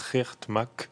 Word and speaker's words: Geert Mak: Je crois qu'on prononce Geert Mak. G2ERT et MAK.